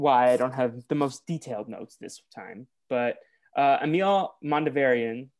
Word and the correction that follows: Mondavarius: did I say that right? – Mondavarius, but it's fine. – Mondavarius shows up and is like, I invited you to this island because spooky things are going on.